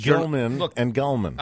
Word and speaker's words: Gelman: I just have this thing where I watched Regis and Kathy Lee, and he would always say Gilman, right? – Gelman.